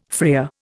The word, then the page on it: freer: Indian